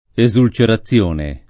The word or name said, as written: esulcerazione